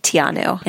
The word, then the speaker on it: Te Anau: How would you describe Te Anau in one word? – majestic. How about you? – Oh, shoot. I said it wrong. How would you describe Tianu in one word? – Majestic.